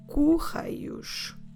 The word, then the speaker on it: correios: We say os correios. Os correios. Como se escreve correios? C-O-R-R-E-I-O-S.